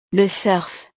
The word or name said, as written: le surf